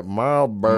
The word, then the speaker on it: Marlboro: It's R and an L, Marlboro. – No, you know, you just need to say it like that. Marlboro.